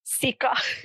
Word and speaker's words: sika: huge embarrassing mistake, I remembered for the rest of my life how do you say whitefish like siika in Finnish, and how do you say pig, which is sika?